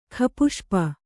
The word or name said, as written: ಖಪುಷ್ಪ